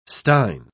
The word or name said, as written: stein